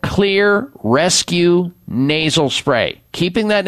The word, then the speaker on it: Xlear: Now, Xlear is spelled with an X, X L E A R.